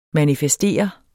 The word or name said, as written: manifestere